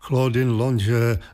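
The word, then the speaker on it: Claudine Longet: května Claudine Lange, vysloveno francouzsky, hlavně v 60.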